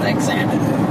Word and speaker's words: Xanadu: It's not about how the words are pronounced? Because you pronounce it Xanadu. – Xanadu.